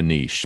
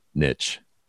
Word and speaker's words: niche: I'm a little French and I know how to say it. – That's it. – I think you're right. I think most Americans say niche.